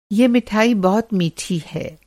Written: मिठाई

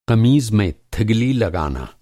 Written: थिगली

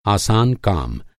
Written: आसान

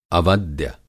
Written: अवद्य

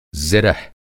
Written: ज़िरह

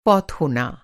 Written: फ़ौत

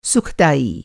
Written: सुखदाई

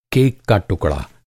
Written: टुकड़ा

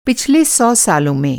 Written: पिछला

